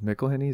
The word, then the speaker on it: McElhenney: Caitlin Olsen, Mac, Rob McElhenney. Is that how you say his name? – Yeah.